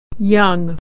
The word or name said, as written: young